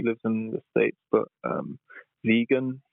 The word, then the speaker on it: Vegyn: Vegan. It's spelled V-E-G-Y-N.